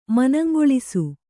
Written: ಮನಂಗೊಳಿಸು

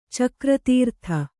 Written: ಚಕ್ರತೀರ್ಥ

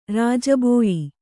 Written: ರಾಜಬೋಯಿ

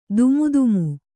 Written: ದೂಮುಧಾಮು

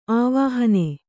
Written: ಆವಾಹನೆ

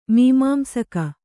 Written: ಮೀಮಾಂಸಕ

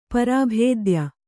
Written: ಪರಾಭೇದ್ಯ

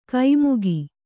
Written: ಕೈಮುಗಿ

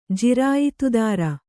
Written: ಜಿರಾಯಿತುದಾರ

